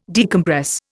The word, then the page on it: decompress: Indian